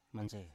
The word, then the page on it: manse: Biak